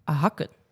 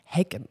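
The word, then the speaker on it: Häcken: Ja, en de laatste groep, dan groep D, is Chelsea, Real Madrid, Paris FC en Hakken. – Of, zoals je het eigenlijk zou moeten uitspreken, Hekken.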